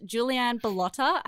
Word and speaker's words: Juliane Bilotta: Her name is Juliane Bilotta.